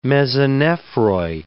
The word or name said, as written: mesonephroi